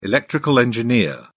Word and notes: electrical engineer: British English

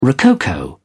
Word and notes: rococo: British English

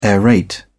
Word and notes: aerate: British English

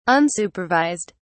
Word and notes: unsupervised: British English

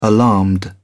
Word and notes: alarmed: British English

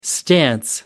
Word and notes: stance: American English

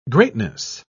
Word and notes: greatness: British English

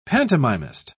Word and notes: pantomimist: British English

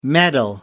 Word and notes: medal: American English

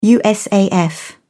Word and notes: usaf: British English

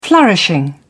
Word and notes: flourishing: British English

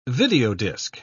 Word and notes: videodisc: British English

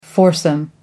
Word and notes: foursome: American English

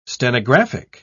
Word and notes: stenographic: British English

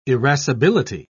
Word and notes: irascibility: British English

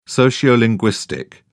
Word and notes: sociolinguistic: British English

